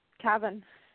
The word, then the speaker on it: Cavan: Cavan.